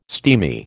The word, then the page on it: steamy: American English